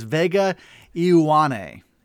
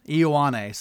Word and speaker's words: Ioane: I think it, um, uh, I had my colleague Daniel Gallen who cited Ryan Snyder from Blue White Illustrated on the pronunciation of the name, which is Vega Ioane. Ioane. So when you look at it, it, it is kind of phonetically if you if you you know just pronounce all the letters there.